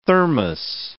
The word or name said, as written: thermos